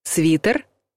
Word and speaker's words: свитер: И каким бы ваш любимый свитер ни был теплым и уютным, слово надо произносить твердо «свитер».